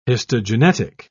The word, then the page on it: histogenetic: British English